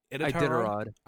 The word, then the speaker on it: Iditarod: Uh, I think it's Iditar. – Iditarod. Iditarod. No, yeah, I did, Rod.